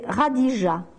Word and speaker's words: Khadija: Alors, son nom, c'est bien de le connaître, de cette femme, c'est Khadija, K-H-A-D-I-J-A. K-H en arabe, ça se prononce R. Khadija, K-H-A-D-I-J-A.